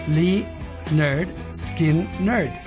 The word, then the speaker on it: Lynyrd Skynyrd: su primer disco de 1973 se llama Pronounce Lee Nerd, Skin Nerd. Entonces, ellos mismos nos estaban diciendo y no nos acordábamos que, claro, el primer álbum así se llama. Para los que dudaban, ahí está. El título del álbum es como pronunciar el nombre de la banda.